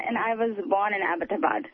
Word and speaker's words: Abbottabad: Abbott Abad. Abbott is like a British name. – Oh, it is like... – Yeah, so it's Abbott and then Abad, like, you know... – Abbott Abad.